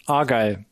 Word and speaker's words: Argyle: Argyle, würde ich jetzt mal aussprechen. – Argyle, ja.